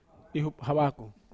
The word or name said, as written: hup hawakou